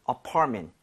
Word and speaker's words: apartment: So 강세를 제대로 주면 MENT가 그렇게 발음이 돼요. 여기 강세 주고 한번 해보면 네. – apartment보다는 apartment. Apartment.